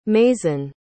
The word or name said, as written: mazin